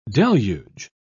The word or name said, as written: deluge